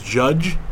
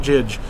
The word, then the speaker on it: Jydge: It's not Jidge? – No. Contrary to a lot of other podcasts and personalities' opinions, no, it is not Jidge. It's spelled J-Y-D-G-E. So it looks like it's Jidge, but if you actually spend, you know, 5-10 seconds in the game, you will come to find out.